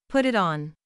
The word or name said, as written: put it on